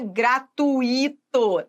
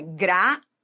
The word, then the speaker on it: gratuito: A pronúncia correta não é gratuito, não. É gratuito. Então, vamos lá. Gratui. Tem que saber pronunciar, senão não vai conseguir identificar a sílaba tônica.